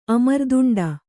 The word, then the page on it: ಅಮರ್ದುಂಡ: kannada